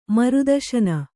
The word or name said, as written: ಮೃತ್ಸ್ನೆ